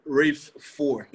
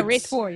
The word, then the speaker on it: Rafe IV: How I found you was with your Rafe track, Rafe IV, or I don't know how to say the numerals. – Oh yeah, Rafe 4. – Yeah, Rafe 4.